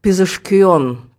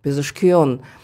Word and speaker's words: Пезешкиан: Фамилия, кстати, на фарси звучит так, как-то так – Пезешкион. Пезешкион. В фарси всегда чередуются буквы «а» и «о», поэтому в русском написании «Пезешкиан», а сами иранцы произносят «Пезешкион».